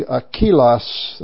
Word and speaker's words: Aquilas: Aquila is in the English. His name is literally Aquilas. That's how it's pronounced.